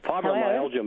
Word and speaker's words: fibromyalgia: Fibromyalgia, Melva, which is basically painful, incredibly painful joints.